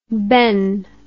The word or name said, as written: Benn